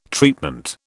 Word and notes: treatment: British English

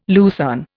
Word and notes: lucerne: Indian

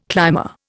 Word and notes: climber: Indian

